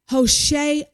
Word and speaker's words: Hoshea: His name was Hoshea, and it's spelled Hoshea. Now, don't get confused with his name versus the prophet of Israel, Hosea, Hosea.